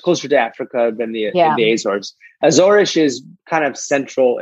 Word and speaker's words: Azores: The Azores is the in English we would say Azores, but Azores is a bastardization of the real pronunciation, which is Azores.